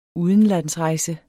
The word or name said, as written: UDENLANDSREJSER